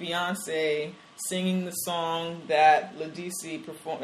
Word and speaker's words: Ledisi: a lot of other places have discussed Ledisi.